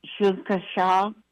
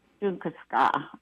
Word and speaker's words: Shunkaska: And the host northern drum is Shunkaska. I hope I said it right. – Ska. – From Albuquerque, S-K-A. – Yeah, that's Ska. – Yeah. Ska? – Yeah. – S-U-N-K-A.